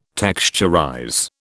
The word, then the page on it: texturize: British English